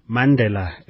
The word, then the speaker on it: Mandela: Mandela is how it's pronounced, as we heard there.